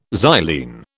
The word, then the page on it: xylene: British English